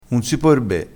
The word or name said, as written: sape di foo